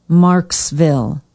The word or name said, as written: marxville